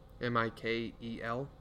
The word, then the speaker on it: Mikel: Michael Horton, M I K E L, Michael, Mikel. I'm sorry.